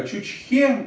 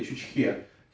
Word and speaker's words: Чучхе: Чучхе, ударение на вот именно на последнюю е, Чучхе.